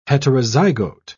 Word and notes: heterozygote: British English

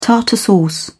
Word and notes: tartar sauce: British English

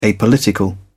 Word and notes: apolitical: British English

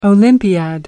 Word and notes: olympiad: American English

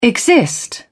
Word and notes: exist: British English

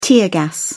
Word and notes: tear gas: British English

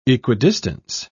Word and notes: equidistance: British English

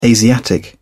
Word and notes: asiatic: British English

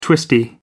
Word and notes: twisty: American English

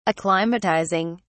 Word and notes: acclimatizing: British English

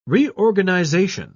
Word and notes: reorganisation: British English

